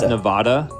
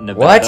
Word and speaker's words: Nevada: That's Nevada, not Nevada.